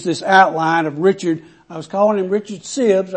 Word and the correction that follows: Sibbes: I have to apologize for that. It's Richard Sibbes. I looked that up and got the pronunciation of that.